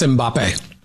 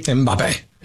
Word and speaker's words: Mbappe: That's Mbappe. – Mbappe? – Mbappe.